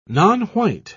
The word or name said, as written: nonwhite